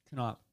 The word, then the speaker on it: Konop: Konop.